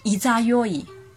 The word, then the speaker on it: 十六夜: 八月十六的月亮称为十六夜，日语发音为伊扎哟伊，伊扎哟伊，伊扎哟伊，伊扎哟伊是徘徊、塔梅拉的意思，形容十六的月亮躲在十五的月亮背后姗姗来迟。